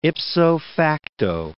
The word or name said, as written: ipso facto